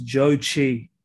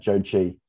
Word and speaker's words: Zhou Qi: I've heard his name pronounced a lot of different ways. I'm not sure if that's a correct pronunciation. – Let me tell you what I found out. – What did you find out? – It's Zhou Qi.